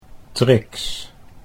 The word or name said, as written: Trix